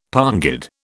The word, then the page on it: pongid: British English